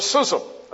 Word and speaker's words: schism: Satisfaction assumes a schism. That's the way you pronounce it.